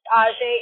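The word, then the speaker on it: Ajhée: There's an accent on the first E, so it's just Ajay, A-J-H-E-E, and I've got a couple videos up, and, that's my, I have a Twilight memes video that I talked about.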